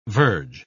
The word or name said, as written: verge